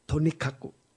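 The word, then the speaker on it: 兎角: 这日文有一个很有意思的话叫 “tonikaku”。这兔脚这两个字他们就念成 Tonikaku Tonikaku Tonikaku，就不是这个意思了，而是总而言之了就是不可能有的啊，纯粹是自心的妄想的意思，叫做龟毛兔脚啊。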